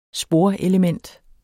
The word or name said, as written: sporelement